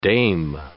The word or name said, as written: dame